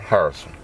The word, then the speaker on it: Harrison: Gerald Harrison. G-E-R-A-L-D. H-A-R-R-I-S-O-N.